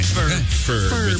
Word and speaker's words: Ferb: Phineas and Herb. – I guess that is a millennial thing. – Ferb. With an F.